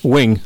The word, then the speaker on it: Wing: Did I say that right? – Wing. – Ken Wing. Yeah, W-I-N-G.